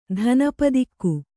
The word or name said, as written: ಧನಪದಿಕ್ಕು